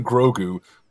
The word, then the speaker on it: Grogu: You guys keep saying Grogu. Grogu sounds like something from TF porn. Like about like turning. Oh, I got my hyper dick now. – But that's how you pronounce his name.